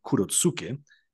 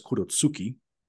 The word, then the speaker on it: Kurotsuki: Está en Video Mind y hay otro efecto de Video Mind muy famoso y lo voy a decir ya mismo, se llama Kurotsuke, que significa luna negra. Max, años después, nos dice que después se dio cuenta que la pronunciación exacta es Kurotsuki, pero a nadie le importa eso, así que les seguiremos llamando Kurotsuke.